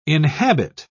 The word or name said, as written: inhabit